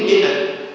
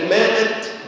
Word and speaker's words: مئة: طيب تعالوا نخطأ تاني هبدأ بيه، كلمة مئة، كلمة مئة، أما نقرأ كلمة مئة ما تقوليش ماء، زي ما ناس كتير جدا بتعمل، يقول لك ماءة جنيه، ألف مئة لا ينطق،